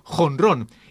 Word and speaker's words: jonrón: Fútbol escrito F-U-T-B-O-L y béisbol escrito B-E-I-S-B-O-L y otros sufren modificaciones tanto fonéticas como escritas. El caso del home run en el béisbol, que se pronuncia jonrón y se escribe J-O-N-R-O-N.